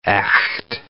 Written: echt

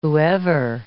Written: whoever

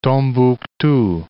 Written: Tombouctou

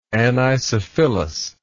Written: anisophyllous

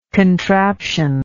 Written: contraption

